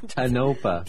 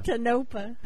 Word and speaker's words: Tonopah: Tonopah. – Tonopah.